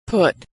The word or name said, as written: put